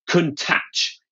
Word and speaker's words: Countach: And that, I am now reliably informed, is the correct pronunciation, Countach. It's got to be quite a hard tach sound, apparently, at the end.